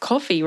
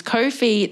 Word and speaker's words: Ko-fi: And it is Ko-fi, the same as no fee.